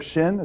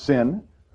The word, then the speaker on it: shin: It's a Greek letter shin. It's sometimes pronounced sin, but it's not sinful.